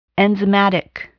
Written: enzymatic

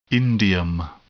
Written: indium

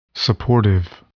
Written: supportive

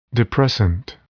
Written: depressant

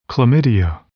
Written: Chlamydia

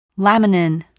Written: laminin

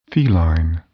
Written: feline

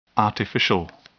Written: artificial

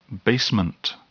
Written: basement

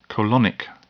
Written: colonic

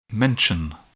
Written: mention

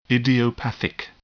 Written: idiopathic

